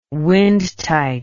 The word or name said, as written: windtight